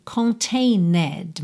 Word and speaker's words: contained: Se escreve contained, mas esse E não é pronunciado, tá? Esquece que eu falei isso, tira esse som da sua cabeça.